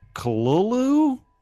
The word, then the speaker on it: Cthulhu: Cthulhu is pronounced Cthulhu? It's what that's what they're saying, which is KHLUL - LOO.